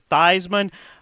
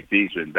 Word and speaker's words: Theismann: To get that, he changed his name from Theismann to Theismann, to rhyme with Heisman that year. But he didn't win it, so we still call him Joey Theismann back home.